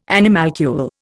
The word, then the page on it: animalcule: Indian